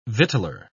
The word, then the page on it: victualler: British English